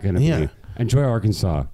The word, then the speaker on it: Arkansas: Dean, it's Arkansas.